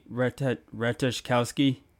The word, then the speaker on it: Ratajkowski: So it's Emily Ratajkowski.